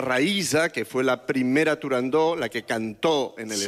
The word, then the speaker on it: Turandot: No se dice, no se debe pronunciar Turandot. – No, Turandó. – La ópera se titula Turandó.